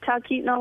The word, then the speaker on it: Talkeetna: Yeah, you said it right, Talkeetna, Alaska.